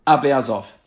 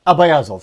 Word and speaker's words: Ablyazov: Ablyazov. – Ablyazov, sorry, that was his name.